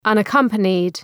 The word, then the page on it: unaccompanied: ,ʌnə’kʌmpənıd